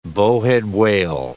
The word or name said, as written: bowhead whale